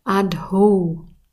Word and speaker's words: Adho: Zuerst mal mit Adho. Da haben wir ein H hinter dem D. Das heißt, wir haben ein D-H, das wir aussprechen müssen. Und es kommt dann zum Adho. Und das O wie auch das E sprechen wir immer lang aus. Und zusammen ist es dann Adho. Das ist der erste Teil.